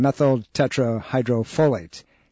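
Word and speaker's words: methyltetrahydrofolate: It's pronounced, give me a second, methyl tetrahydrofolate. See, I remember that.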